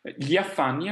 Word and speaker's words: gli affanni: Gli affanni, anche qui abbiamo la stessa consonante gli, in questo caso però poi abbiamo una A, e quindi la I di gli serve appunto a pronunciare questa consonante un po' particolare. La I però non è assolutamente lunga, noi pronunciamo andando direttamente sulla A, gli affanni.